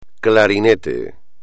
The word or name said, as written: clarinete